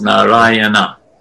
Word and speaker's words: Narayana: Four syllables, Narayana.